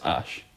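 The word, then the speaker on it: Æ: The X is X, and that like the A thing is pronounced Ash.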